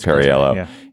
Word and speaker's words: Perriello: I can never pronounce his name. It is Perriello, in the Democratic primary.